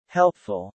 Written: helpful

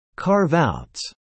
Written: carve-outs

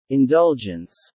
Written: indulgence

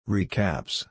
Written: recaps

